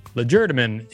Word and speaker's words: legerdemain: Legerdemain is skillful use of one's hands when performing conjuring tricks. – Oh. – It is pronounced legerdemain. All right. I was saying it right.